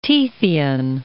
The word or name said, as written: Tethyan